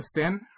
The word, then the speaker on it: Seine: And she sings, she leapt into the Seine, her aunt leapt into the Seine, the river. But again, using the French pronunciation of the word scene, uh, Mia leaps into the scene into song right after she sings that she that's the moment where she starts singing.